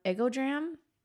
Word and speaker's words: Egodram: So her Instagram is called Art of Egodram.